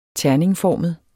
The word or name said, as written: terningformet